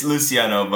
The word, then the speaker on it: Luciano: Yeah, it's Luciano, but you got it right.